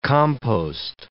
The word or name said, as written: compost